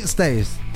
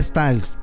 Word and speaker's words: Styles: La canta Harry Styles. – Ah, muy bien. – ¿Lo dije bien? – Pues ahí vas. – Ah, Styles, perdón.